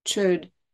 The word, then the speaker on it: chöd: Se escribe C-H-O con los dos puntitos arriba, D, chöd.